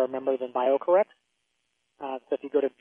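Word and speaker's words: BioCorRx: B-I-O, bio, and it's C-O-R-R-X, and pronounced BioCorrex, like you're correct.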